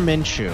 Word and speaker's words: Minshew: So Minshew, is that how we say his name? Minshew? – Yep. Gardner Minshew.